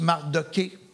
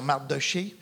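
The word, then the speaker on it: Mardochée: Et il semble qu'Esther et son oncle Mardoché, il y en a qui prononcent Mardoqué, mais c'est, en tout cas, moi je vais dire Mardoché, et les deux cachaient leur identité juive parce qu'ils vivaient dans un empire qui pouvait être violent et qui était anti-juif.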